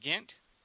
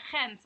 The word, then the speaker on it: Gent: Is it pronounced Ghent? – Gent, actually, but just say Ghent.